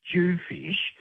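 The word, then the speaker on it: dhufish: That's how you spell it. And it's pronounced Jewfish.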